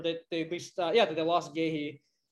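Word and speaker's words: Guéhi: Honestly, I'm just hyping on the Gihi train. I can't pronounce his name. – Gehi, thank you.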